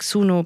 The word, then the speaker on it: Suno: Ich habe gesehen, ihr arbeitet zusammen mit, ich hoffe, ich spreche die alle richtig aus, OpenAI, Anthropic, Suno, Perplexity, Midjourney, Cognition, 11 Labs, LangChain, Pinecone. Habe ich was falsch ausgesprochen? – Die sind alle perfekt tatsächlich.